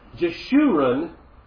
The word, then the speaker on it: Jeshurun: Jeshurun was the most dear to him, was the most dear to the Lord, but became fat.